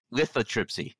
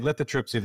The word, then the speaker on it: lithotripsy: lithotripsy. Did I say that right? – You did. Lithotripsy.